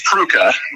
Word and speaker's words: Prucha: Arrington Prucha Prize, is that right? – I think it's Pruka, but Pruka.